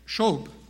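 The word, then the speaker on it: shub (שוב): This word, turn from, is a Hebrew word, shub. It's spelled S-H-U-W-B.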